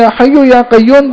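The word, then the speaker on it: یا حی یا قیوم: تو انہوں نے طریقے سے اصلاح کی, کہا کہ میرے عزیز یہ جو وظیفہ آپ پڑھ رہے ہو نا, تو یا حیو یا قیوم ہے.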